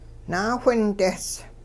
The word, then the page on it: naawhindehs: Hupa